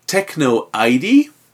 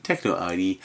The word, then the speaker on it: Teknoaide: Right, this is Techno ID. I'll spell that for you. T-E-K-N-O-A-I-D-E, Techno ID, and Iconobreaker, with a track called Luonon Nostatus.